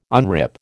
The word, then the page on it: unrip: American English